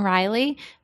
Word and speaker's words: Reilly: Mine is, hi, Caitlin Riley. It's Caitlin with a C, and Riley is spelled R-E-I-L-L-Y.